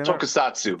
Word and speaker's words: Tokusatsu: Did I say Tokusatsu.